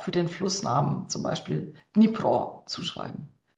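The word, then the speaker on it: Dnipro (Дніпро): Und deswegen wurde es dann ins Deutsche als Kiew integriert, aber das ist tatsächlich die russische Aussprache, genauso wie der Fluss Dnieper, ne, also auf Russisch, auf Ukrainisch der Dnipro ist.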